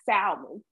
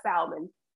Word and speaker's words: salmon: It is pronounced salmon, not salmon. It is not salmon. The L is silent.